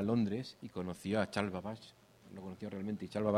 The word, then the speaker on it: Babbage: Babbage. Babbage.